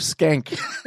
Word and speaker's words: Schenck: Joseph Skank. – It's spelled S-C-H-E-N-C-K. – Oh, my God. – Pronounced Skank. Skank.